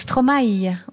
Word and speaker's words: Stromae: On prononce Stromae.